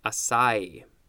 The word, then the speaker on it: açai: Next we have A-C-A-I, açai. That's how it's pronounced.